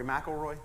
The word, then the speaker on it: McIlroy: I like to tell the folks at McIlroy Road, I'm going to keep saying that so you know it, say it right. Think of the golfer, Rory McIlroy. It's not spelled the same, but it's pronounced the same.